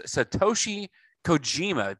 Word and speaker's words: Satoshi Kojima: Next up on the card, we've got John Moxley versus Satoshi Kojima.